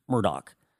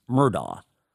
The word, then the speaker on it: Murdaugh: By the way, he pronounces it Alec Murdoch. I'm not being ESL here when I say it this way. It is spelled. You and I would say it Alex Murdaugh, but he pronounced it Alec Murdoch because this is the South Carolina Lowcountry.